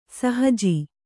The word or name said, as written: ಸಹಜ